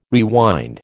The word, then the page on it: rewind: American English